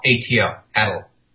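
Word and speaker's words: atlatl: ATL, ATL, Attle. – Attle? – Attle. Lattle. The word is pronounced at lattle or at. So it's A-T-L, A-T-L.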